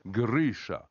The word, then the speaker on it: Grisha: Grisha.